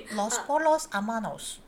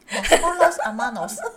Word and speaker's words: Los Pollos Hermanos: Los Pollos Hermanos，哇！你識得 pronounce 真係勁，再講多一擺。 – Los Pollos Hermanos，